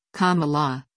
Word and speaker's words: Kamala: Harris' first name is pronounced Kamala, or, as she explains in her biography, Kamala, like the punctuation mark.